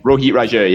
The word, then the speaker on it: Rohit Raju: was it Roti, what's his name? – Rohit Raju, yeah. Sorry, I mispronounced that.